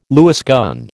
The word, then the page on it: Lewis gun: American English